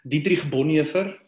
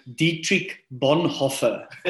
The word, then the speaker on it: Dietrich Bonhoeffer: Dietrich Bonhoeffer, that's me pronouncing a German name with an Afrikaans accent, right? Quite tough. If you want to go all English, you can go Dietrich Bonhoeffer. I've heard that people also pronounce his name in that way.